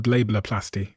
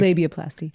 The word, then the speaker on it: labiaplasty: A lot of people are having this new procedure called labiaplasty. Did I pronounce that correctly? – Labiaplasty. – Labiaplasty. – Yeah.